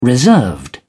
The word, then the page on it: reserved: British English